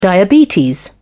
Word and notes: diabetes: British English

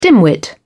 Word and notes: dimwit: British English

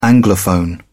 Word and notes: anglophone: British English

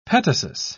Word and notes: petasus: British English

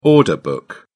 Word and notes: order book: British English

